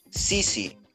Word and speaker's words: CC: también, también hay un trasfondo, porque cuando estábamos pensando en el nombre, dijimos Sisi. Y Sisi, o sea, la C en inglés se pronuncia sí. Y como es CC, entonces sí, sí.